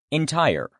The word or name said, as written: entire